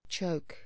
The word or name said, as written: choke